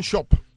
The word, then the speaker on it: Schoop: El equipo de Minnesota llegó a 300 antes que los Yankees de Nueva York, y fue Jonathan Schoop. ¿Se dice así? – Ellos dicen Schoop.